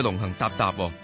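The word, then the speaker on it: 龙行龘龘: 最近有一個咧就非常之火紅嘅说话，我睇翻个读音咧叫龙行搭搭，成为咗咧就系内地最热嘅、最火红嘅一句祝福语。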